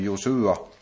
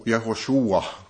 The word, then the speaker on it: Josua: Och när Mose dog, så fortsatte Josua, eller egentligen jag ska säga att det här, vi brukar här i vår finländska tradition uttala det här lite fel. Det här namnet i Sverige säger man Josua, och det är närmare Jehoshua kanske man skulle säga, om man skulle säga det på riktigt.